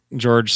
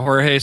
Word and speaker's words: Jorge: Oh, is that how you say it? I was going to say George Soler. anyway, yeah. Jorge Soler has been rehabbing with, Myrtle Beach, who's been in the playoffs, and he played four games.